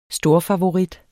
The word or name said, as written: storfavorit